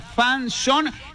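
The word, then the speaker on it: fanzone: Atención, palabra fanzón, que se escribe fanzón, con Z, F-A-N-Z-O-N-E.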